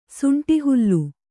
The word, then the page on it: ಸುಂಟಿಹುಲ್ಲು: kannada; suṇṭi hullu